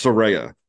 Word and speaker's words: Saraya: so there's a, a, a person I used to you know have friends back in the day, um, and her name was uh, Soraya. So I always have botch it too because I just think you know I've, I've I've heard that name before and always pronounce it this way. But yes, it's that it's Soraya. Okay.